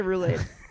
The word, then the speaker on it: roulade: I like it when you say roulade. You should say roulade.